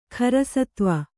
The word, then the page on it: ಖರಸತ್ವ: Kannada